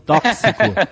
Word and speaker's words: tóxico: C, CS, tóxico.